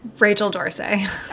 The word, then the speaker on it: Rachel D'Orsay: I said that right, right? Rachel Dorsey? Rachel yeah. Dorsey? – Rachel Dorsey.